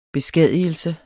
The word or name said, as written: beskadigelse